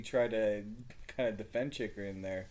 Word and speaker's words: Chychrun: Chychrun, you gotta, if you're gonna insult the guy, at least say the name properly, Chris. – Chychrun, yeah, jeez.